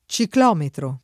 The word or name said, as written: ciclometro